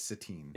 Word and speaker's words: Satine: I'm assuming that's Satine. And it's not satin with a silent E. I believe it's Satine.